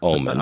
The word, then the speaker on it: Ommen: And when he pulled himself out of the wreckage, there was a road sign for this nearby town, which is spelled O-M-M-E-N, which is Omen.